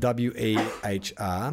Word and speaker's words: Wehr: The surname is spelled W E H R. I'm going to say Jacob. Jacob Wehr, as in to wear clothes.